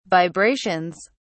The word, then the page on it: vibrations: British English